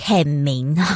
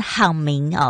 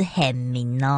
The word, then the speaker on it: 很明: 你现在所收听的节目是时时好心情，这首歌来自浅提的很明，每次我差点要念成好明哦，是很明哦。